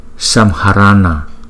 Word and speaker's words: Samharana: Samharana, Samharana, Samharana, Samharana, Samharana. Các bạn chú ý cái chữ N có dấu nặng ở dưới, chúng ta phải đọc là N, N, N, N.